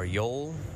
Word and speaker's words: Joel: Now, for the Canadiens, we have Thomas Tatar, Nick Suzuki, and Brendan Gallagher, Jonathan Drouin, Jesperi Kotkaniemi, and Joel Armia, or Yol, as how I've heard people say his name, Paul Byron, Philip Deneau, and Arturi Lekkonen, Dale Weiss, Max Domi, and Alex Belzile, as rounding out your forwards.